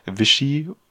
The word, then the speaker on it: Vichy: Also im Norden war deutsche Besatzung und im Süden gab es das, wir haben da gerade eben auch schon ein bisschen über diese Aussprache debattiert, Vichy. – Ich glaube, das ist richtig, ja.